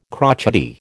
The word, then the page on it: crotchety: American English